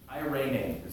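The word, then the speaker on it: eirene (εἰρήνη): Well, the word he uses here is a Greek word, eirene, is how it's pronounced.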